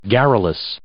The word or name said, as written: garrulous